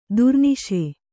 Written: ದುರ್ನಿಶೆ

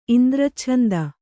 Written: ಇಂದ್ರಚ್ಛಂದ

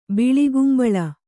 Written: ಬಿಳಿಗುಂಬಳ

